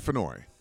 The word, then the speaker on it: Fennoy: We're with Con Air Radio, and I have the amazing opportunity to talk to one of the most awesome voice actors ever, Dave Fennell, I believe is how it's pronounced. – Dave Fennoy. – Fennell. Okay, I was close. – Yeah, it's like Annoy, only Fennoy.